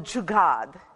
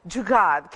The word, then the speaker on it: jugaad: There is a word called jugad. It's a Hindi word. Jugad. Can you say it with me? Jugad. J-U-G-A-A-D. If you translate it phonetically in English, jugad.